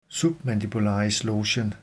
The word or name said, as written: submandibularislogen